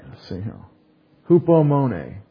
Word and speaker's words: Hupomone: H-U-P-O-M-O-N-E. Long E. That's a European or Greek E, A. It says A. All the other languages that are kind of like English kind of say the long A sound in English for the long E.